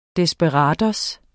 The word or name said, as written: desperado